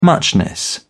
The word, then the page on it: muchness: British English